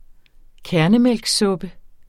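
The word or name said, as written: KÆRNEMÆLKSSUPPE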